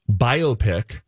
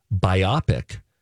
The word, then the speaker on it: biopic: By the way, biopic is the American pronunciation. Biopic is the British pronunciation.